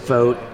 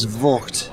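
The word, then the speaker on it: Voogt: So it's V-O-O-G-T, and it's pronounced vote in an like you vote in an election.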